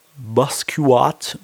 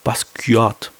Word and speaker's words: Basquiat: So, ähm, dann kommen wir zu Maris und Bruno Betty gegen Basquiat und ja, Team Bascu. ich kann das gar nicht aussprechen, ey. Basquiat. – Basquiat.